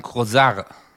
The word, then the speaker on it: Kosar: Eigentlich heißt, also richtig ausgesprochen, ist es Özcan und dann Kosar. Eigentlich vom Französischen.